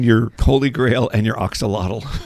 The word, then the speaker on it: Axolotl: Axolotl. Well, I looked it up, and I phonetically wrote A-K-S-U-H-L-A-W-T-A-L. Axolotl.